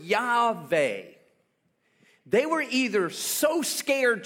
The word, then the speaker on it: Yahweh: His proper name is spelled Yod-Heh-Vah-Heh. We pronounce it Yahweh.